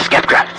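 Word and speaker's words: sceptre: Many of the most capable warriors have gone in search of the Spectre. Of the Skeptra. Sorry about that. Mispronunciation.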